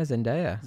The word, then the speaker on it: Zendaya: Yeah, Zendaya.